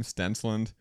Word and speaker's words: Stensland: My old last name, Stensland. Everyone always just throws a weird letter in there. Strensland, Steinsland, something like that.